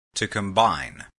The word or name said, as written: mix